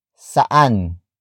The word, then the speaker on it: saan: Saan nila dadalhin ang bahay? Saan nila dadalhin ang bahay? Saan nila dadalhin ang bahay? It means, where will they carry the house? Word for word, it's saan nila dadalhin ang bahay?